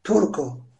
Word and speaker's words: Turco: Turku. T, U, R, C, O. Burada C'ye dikkat etmek gerekiyor. C gibi değil, K gibi okunuyor. Çünkü sonradan sonrasında O harfi geliyor.